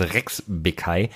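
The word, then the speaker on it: Rexhbeçaj: Also wenn du ihn zum ersten Mal liest, weißt du nicht, wie man ihn ausspricht, heißt Elvis Rex Bekai.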